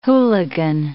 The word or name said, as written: hooligan